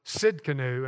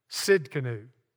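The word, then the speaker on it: Tsidkenu: Now, the next one is Jehovah Sidkenu, and let me spell that for you because it doesn't spell like it sounds. It starts with a T, T-S-I-D-K-E-N-U, Jehovah Sidkenu. And that means the Lord is our righteousness, and that's Jeremiah chapter 23 and verse 6.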